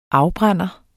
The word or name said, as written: afbrænder